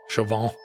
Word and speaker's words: Chauvin: And then the affiliate preempted, she must be from the Twin Cities because she said it got preempted for the Derek Chauvin trial. – Oh, sure. – Am I saying that right? – Yeah. Chauvin.